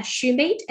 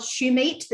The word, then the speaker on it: Shumate: You can send me an email at Shumate, S-H-U-M-A-T-E, at northwestern.edu. That's where I'm a professor. Or you can find me on LinkedIn. I'm the only Michelle Shumate that's spelled my way, because evidently that's an unusual enough name that works on LinkedIn.